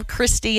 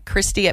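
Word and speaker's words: Cristy: Yeah, just call, email Christy, and it's spelled weird.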